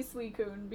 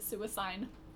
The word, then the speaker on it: Suicune: My third favorite one is probably Suicune, because I always try to pronounce it Suicine for some reason. – Yeah, it's... Oh, no, it's not right. – Yep, it's not right. Completely wrong.